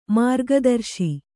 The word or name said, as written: ಮಾರ್ಗದರ್ಶಿ